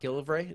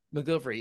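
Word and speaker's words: McGillivray: We should mention, how do you pronounce Scott's last name? Scott Gilvray. Is that right? – McGilvery.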